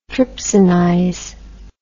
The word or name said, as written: trypsinize